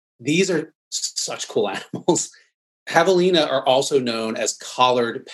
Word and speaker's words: javelina: I hope I'm pronouncing that right, but it's javelina. – Oh, javelina. – Yep. The J is the H sound.